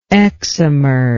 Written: excimer

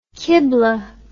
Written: qiblah

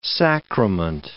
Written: sacrament

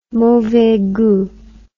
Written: mauvais gout

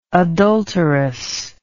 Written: adulterously

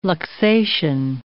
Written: luxation

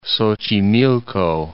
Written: Xochimilco

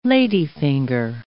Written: ladyfinger

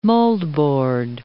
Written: moldboard

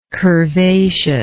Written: curvaciousness

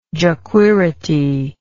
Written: jequirity

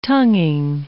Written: tonguing

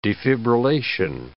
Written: defibrillation

